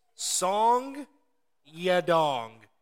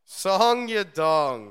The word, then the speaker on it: Song Yadong: His name is Song Yadong. – Song Yadong. – S-O-N-G space Y-A-D-O-N-G. You heard that right here, folks. Song Yadong, he is fighting Ricky Simon this weekend.